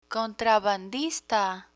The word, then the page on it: contrabandista: Spanish